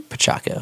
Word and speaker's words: Pacheco: Pacheco. – That is the Italian way.